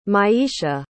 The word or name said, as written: myeisha